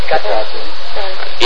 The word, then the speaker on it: Hecate: I don't know how you pronounce it in English, but... – Hecate. – Yeah, well, the stress is on in the beginning.